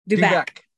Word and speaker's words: Dubec: Dubec.